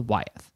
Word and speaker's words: Wyeth: Wyeth, W-Y-T-H-E? – Yeah, did I mispronounce? – No, not at all. I was just wondering. I was wondering if it's like Wyeth, like, you know, all the things, like Wyeth Avenue. – W-Y-E-T-H is how I found this.